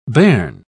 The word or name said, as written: bairn